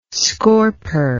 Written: scorper

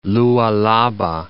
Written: Lualaba

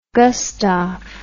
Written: gustaf

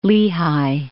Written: Lehigh

